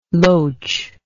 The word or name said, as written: loche